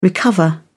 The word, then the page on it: recover: British English